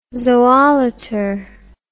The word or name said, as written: zoolater